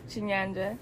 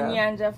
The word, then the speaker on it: Chinyanja: She speaks Chiancha. Is that how you say it? – Close enough. Chinyanja. – There you go. Nyanja for short.